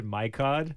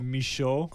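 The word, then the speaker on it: Michôd: So this one, the director, I used to say his name as David Mikod. – Oh, yeah. – The Australian, but then when he came here with The King, with Timothy Chalamet's movie, he came to Busan, and is David Michaud.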